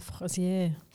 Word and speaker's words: Fraisier: Now, you talked to me about a really beautiful strawberry cake, and I think we've got the pronunciation right, which is Le Fraisier. – Yes, we're speaking French and it's a Fraisier cake.